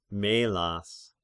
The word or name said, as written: Melas